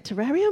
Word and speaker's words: terrarium: How do you say it? Terrarium? – Terrarium. – Terrarium, there you go. – Like aquarium. – Aquarium. – But instead of aqua, it's terra.